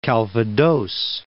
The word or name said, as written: Calvados Reef